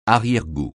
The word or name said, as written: arrière-goût